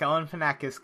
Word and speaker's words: Galifianakis: Zach Galifianakis comes into the ring.